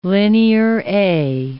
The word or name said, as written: Linear A